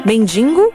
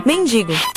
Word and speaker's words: mendigo: Não sei, acredito mesmo que a pessoa que pronuncia mendigo, quando vai escrever, vai tentar colocar esse N aí que não existe. Então, você deve falar mendigo. Digo, mendigo, não mendigo.